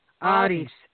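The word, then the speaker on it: Ares: I do my best with my understanding of modern Greek, but I think it's Eris, isn't it? For the goddess of war. – Aris. – Aris. Yes, so yes.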